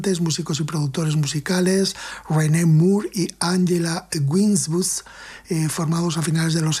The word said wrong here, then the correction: Winbush: Bueno, se llama Winbush, por pronunciar bien su apellido.